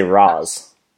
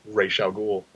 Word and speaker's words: Ra's al Ghul: But he used to say Roz. – Yeah, he used to say Roz, which is why everybody else said Roz, because they only had his pronunciation to go on. Even after Ra's showed up and pronounced it Ra's al Ghul, and they were still like, Roz? – Well, they had... – You're saying it wrong, sir.